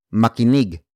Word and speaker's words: makinig: For example, makinig ka, makinig ka, makinig ka. It means listen up. Makinig is a ma actor focused verb. Word for word, it's makinig ka, makinig ka, makinig ka.